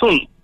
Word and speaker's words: film: Like, it's not film. It's film. Like, how do I get away? How do I get rid of the L? Film. It's not, no. It says film. So I should pronounce it film.